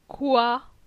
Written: Coi

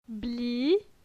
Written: Bli

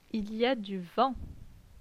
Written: Il y a du vent

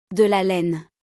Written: De la laine

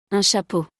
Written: Un chapeau

